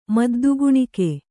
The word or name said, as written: ಮದ್ದುಗುಣಿಕೆ